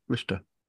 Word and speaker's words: Worcester: How do you say Worcestershire sauce? – Worcestershire. – Worcester? – Yeah, Worcester. – Worcester. Is that that's the correct pronunciation, isn't yeah.